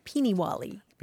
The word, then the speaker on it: Peenie-wally: Peenie-wally. – Peenie-wally. Sounds familiar, yeah. – Yeah, it's spelled lots of different ways, like P-E-E-N-I, and then another word, Wally, mm-hmm. – like the name Wally, Peenie-wally.